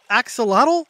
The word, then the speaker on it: axolotl: It's spelled A-X-O-L-O-T-L. Axolotl? Axolotl? Am I pronouncing that right? – Yup, nailed it.